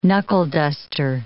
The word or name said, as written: knuckle-duster